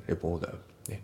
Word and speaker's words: reporter: あと発音ですけど、レポーターの t がね、 d で濁ります。レポーダー、ね。